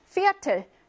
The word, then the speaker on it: Viertel: Viertel. 얘는요, 순수 독일어입니다. 맨 처음에 오면 독일어일 확률이 높다고 했잖아? 얘는요, F처럼 발음하고요.